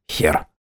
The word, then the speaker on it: хер: Я тут же вспомнил, что диагональный крест является 27-й буквой в древнерусской азбуке, или буковице, и произносится эта буква именно «хер».